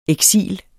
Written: eksil

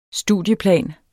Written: studieplan

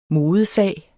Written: modesag

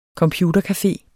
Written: computercafé

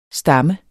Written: stammer

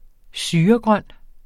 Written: syregrøn

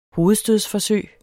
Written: hovedstødsforsøg

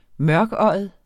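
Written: mørkøjet

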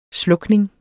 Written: slukning

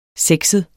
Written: sexet

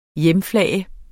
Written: hjemflage

